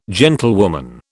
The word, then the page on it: gentlewoman: British English